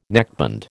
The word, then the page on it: neckband: American English